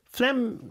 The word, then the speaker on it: phlegm: Phlegm 스펠링이 PH예요. – 네. – PHLEGM인데 어떻게 읽냐면 phlegm 이렇게 읽죠.